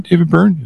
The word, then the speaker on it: Byrne: David Byrne of Talking Heads, right? – Mm-hmm.